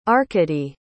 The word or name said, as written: arkadiy